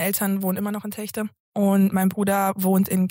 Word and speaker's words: Telgte: Also die Leute, die dort wohnen, wissen, wie es ausgesprochen wird. Also der erste Versuch bei vielen ist dann Techte. – Ja. – Wenn man aber dort wohnt, wird es Techte. Techte. Mit dem CH, genau, okay.